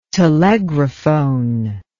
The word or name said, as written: telegraphone